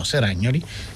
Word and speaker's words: Seragnoli: Leonardo Guerra Seragnoli, credo si dica, no, Seragnoli, credo che sia l'accento giusto, che è il regista di Last Summer, eh, Like Me Back, sta preparando una nuova versione degli Indifferenti ah, da, da, Moravia.